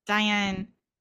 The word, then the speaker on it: Dionysus: Dionysus.